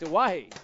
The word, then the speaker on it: Oahe: Am I saying this right? Oahe. – You're saying it right. Correct. That's correct. Oahe.